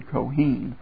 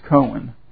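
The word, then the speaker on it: Kohen (כהן): because that's the Hebrew word, Kohen, for priest. Only in our English it's often just pronounced Cohen.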